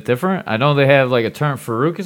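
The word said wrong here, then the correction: farang: is it? How do you say it? – Farong.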